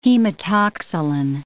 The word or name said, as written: hematoxylin